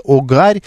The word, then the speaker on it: огарь: Ну и Елена, наши слушатели любят нас поправлять, пишет, что ударение в слове «огарь» первый слог.